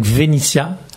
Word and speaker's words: Venythia: Un joli groupe qui s'appelle donc Venitia. – C'est ça. – Ça s'écrit V-E-N-Y-T-H-I-A.